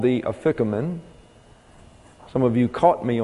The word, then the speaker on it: afikoman: And so the way you pronounce this is afikamein, afikamein.